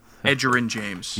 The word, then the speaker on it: Edgerrin: One more time on that last name. – Edgerrin James. Is that how you say it? – Oh my God. Edgerrin James.